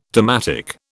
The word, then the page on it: dalmatic: British English